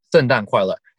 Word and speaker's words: 圣诞快乐: I keep every year during Christmas time, yeah, instead of saying, Xin Dan Kuai Le. Xin Dan Kuai Le. Xin Dan Kuai Le. – That's not right. – How do you say it? – 圣诞. – 圣诞快乐.